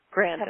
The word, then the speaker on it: Grandin: Grandin.